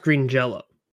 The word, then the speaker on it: Green Jelly: And that's for Green Jello, Three Little Pigs. Now, the band's name is spelled Green Jelly, but it's pronounced Green Jello. – Yeah. – Because they originally had a J-E-L-L-O, but they got a cease and desist letter from the company.